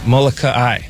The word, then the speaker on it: Molokai: It's a Molokai. Molokai. – Molokai. – Yeah, it's not Molokai. It's Molokai. – No, it's not Molokai. – It's Molokai. I learned that.